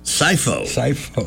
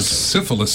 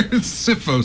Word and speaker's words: Syfo: S-Y-F-O. – And what did he mean? – Sifo. – Not syphilis seltzer. Sifo seltzer.